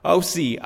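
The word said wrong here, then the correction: awi (οὐαί): ausi, ausi." This is the voice an eagle makes when he flies. When he flies over the heavens. Sorry, there's no S. It's awi, awi, awi. That's how the word is pronounced in Greek.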